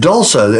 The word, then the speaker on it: Dulsa: It's called that Dulsa. It's a sugar substitute. – Spell it. – It's D-U-L-S-A. – Dulsa, D-U-L-S-A, okay?